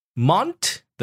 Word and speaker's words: Mont: But in French, Mont, the T, is omitted. So Mon. And then Blanc, B-L-A-N-C. Some people in Northern America, also in Europe or myself, I would pronounce everything Mont Blanc. – Yeah. – Okay. I used to do that. And then once you get educated about the French pronunciation, you will omit the T and the C.